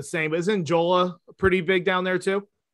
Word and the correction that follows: Jollof: Is it Jola or Jala? – Jollof. – Jollof, yes, that's it.